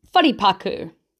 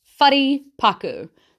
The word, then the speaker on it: wharepaku: So let's start with bathroom. Whare-paku. Whare-paku.